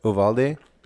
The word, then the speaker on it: Uvalde: Uvalde? – Yeah, yes.